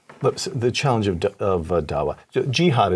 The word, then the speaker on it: Dawa: Dawa is the way it's pronounced.